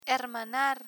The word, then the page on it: hermanar: Spanish